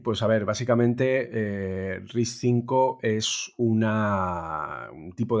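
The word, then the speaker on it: RISC-V: Todo el tema de RISC 5, que se escribe RISC V, eh, para que lo que queráis buscar en Google, es muy complicado.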